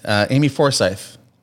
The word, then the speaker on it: Amy Forsyth: This is Miss Amy Forsyth.